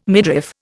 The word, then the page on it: midriff: Indian